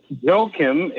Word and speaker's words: Joachim: So Joachim is how you pronounce it in English.